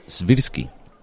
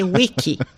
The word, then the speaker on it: Svirsky: Oye, te felicito como has pronunciado lo de Svirsky. El único andaluz que lo pronuncia bien. – Oye, pues estaba preocupado, ¿eh? – Del tirón, porque todo el mundo dice whisky.